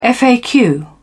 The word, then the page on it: faq: British English